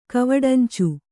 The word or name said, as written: ಕವಡಂಚು